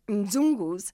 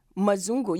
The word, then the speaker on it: Mzungu: So example, Mzungus who are not familiar with Swahili or Kiswahili might say Mzungu. You want to make it a syllable, but the M is just so little. Mzungu. It's spelled Mzungu. It's a double consonant. So Mzungu.